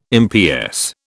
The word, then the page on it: MPS: British English